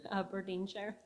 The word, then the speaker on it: Aberdeenshire: Aberdeen Sharon, Scotland. I'm glad I said that right. Aberdeenshire.